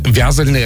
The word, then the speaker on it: вязальное: Вязальное — это у них правильно вот такое ударение, да? – То есть она вязальщица.